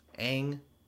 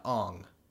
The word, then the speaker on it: Aang: That's more so about changing the pronunciation of his fucking name. Aang to Ong. It's fucking stupid. – Yeah. – I don't care there's two A's in it.